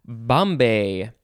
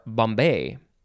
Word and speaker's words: bombé: Bomb or bombé.